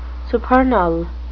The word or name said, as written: supernal